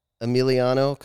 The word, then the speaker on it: Emiliano: Well, I'm sitting here with Emiliano Cataldi.